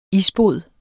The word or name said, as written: isbod